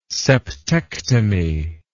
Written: septectomy

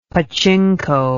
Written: pachinko

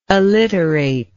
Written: alliterator

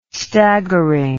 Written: staggering

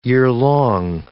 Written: yearlong

